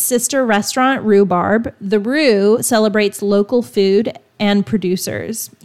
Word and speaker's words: Rhu: And one more place for coffee, I would recommend The Roo. The Roo, and that's spelled R-H-U, is a stylish bakery, cafe, and grocery offering Appalachian bites and pantry staples in Asheville.